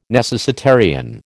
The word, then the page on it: necessitarian: American English